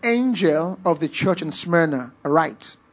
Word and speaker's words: Smyrna: Smyrna is spelled S-M-Y-R-N-A, Smyrna.